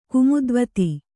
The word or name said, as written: ಕುಮುದ್ವತಿ